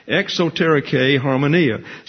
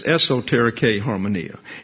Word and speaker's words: exoterike harmonia: See, this letter here is a long O, and I indicate that there. It's like a long O, X-O. And this is a short E here, exoterike. And see, that's an E, but a line over it, and it's pronounced like a long A in English. So that's actually an A sound here. And then harmonia, it's a short O, sound like a short A. And so exoterike harmonia, the same thing down here, except it's an S, esoterike harmonia.